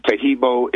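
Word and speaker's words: Taheebo: And Tahibo is the only tricky part to spell it, is T-A-H-E-E-B like boy, O. And then the second word is T-T-E-A. And the third word is club, C-L-U-B.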